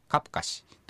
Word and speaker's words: クアプカシ: 独り言を言いながら、クアプカシですね、サル方言だからクアプカシはカプカシというように、発音されます。